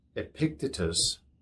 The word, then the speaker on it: Epictetus: It's a tough name to pronounce, but Epictetus, E P I C T E T U S.